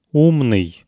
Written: umnyy

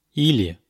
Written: ili

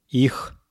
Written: ih

